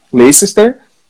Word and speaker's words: Leicester: Leicester que foi campeão da Premier League, né, e continua brigando até lá em cima hoje, joga muito, cara, igual o cantei.